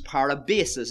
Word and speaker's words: parabasis: Transgression, if you want to divide up these various words for sin, in the Greek language it's a word pronounced parabasis, and it means rebellion.